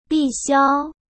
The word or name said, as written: bixiao